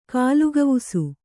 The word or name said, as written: ಕಾಲುಗವುಸು